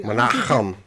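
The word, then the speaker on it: Menahem: Are you pronouncing that correctly, by the way? – Menachem. – Thank you. – I mean, that's how Elliot Gould said it.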